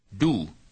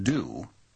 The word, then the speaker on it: du: Du, not do, as in doom.